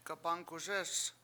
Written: gabAngkuSEEs

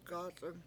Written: gAc7ung

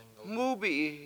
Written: MUUbi7i

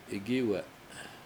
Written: igIwe7

